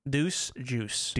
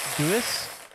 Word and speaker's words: Deuce: It's also spelled D-U-I-C-E. It could be dice. – No, it's like juice. I think it's pronounced think it's like juice. – Yeah, it's like juice. Okay. – Deuce. – Yeah. – Deuce.